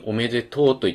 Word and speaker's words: おめでとう: まさにおめでとうと言った時のこの最後の音ですね。字面では、とうっていうふうに書いてますけど、発音では、超音で、おめでとうとなりますよね。